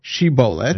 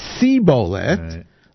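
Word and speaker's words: shibolet (שיבולת): Say this word, shibolet. – And say how you pronounce it. – And if he said sibolet, the lo yachin lidaber kein, they were not able to pronounce it correctly.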